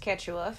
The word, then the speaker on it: Quechua: It's pronounced Quechua.